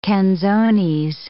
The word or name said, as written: canzones